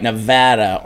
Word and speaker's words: Nevada: Say it right. Nevada on February 1st.